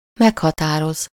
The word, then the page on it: déterminer: /de.tɛʁ.mi.ne/